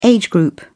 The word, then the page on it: age group: British English